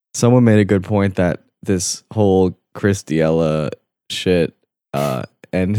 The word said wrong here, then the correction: Chris D'Elia: did I say his name wrong again? Chris Thiella. Chris D'Elia.